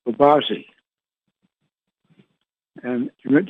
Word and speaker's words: Babaji: Babaji, might be pronounced Babaji. – Yes, yes. Right, that's correct.